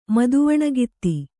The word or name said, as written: ಮದುವಣಿಗಿತ್ತಿ